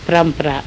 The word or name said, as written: Prampra